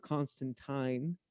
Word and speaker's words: Constantine: And you know, they all have done Constantine yeah. – or Constantine, as I'm told it's actually pronounced, but fuck it, I'm American.